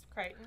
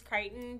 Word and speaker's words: Crichton: It's Crichton. – Okay. – I've heard people over enunciate the ch, so it would be like Crichton, That's Christian. Just wrong. – I only happen to know it's pronounced Crichton because the neighborhood I live in spells it the exact same way, and it's pronounced Crichton.